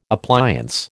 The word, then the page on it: appliance: American English